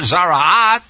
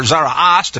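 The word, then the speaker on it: Tzaraat: This is a double portion, and it is the story about the person who has last week's main topic from the Torah, Zara'at, or Zara'as, depending upon whether you're Sephardic or Ashkenazi in pronunciation.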